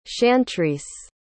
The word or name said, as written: chantrice